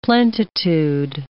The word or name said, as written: plentitude